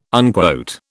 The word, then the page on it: unquote: British English